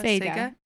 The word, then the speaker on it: Sega: That's how Sega.